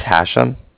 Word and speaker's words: Hashem: My name is Hashem, and phone number is 858. – Can I spell that? – Yeah, H-A-S-H-E-M, as in mother at the end.